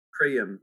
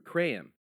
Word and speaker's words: Krayem: Krayem. – Krayem.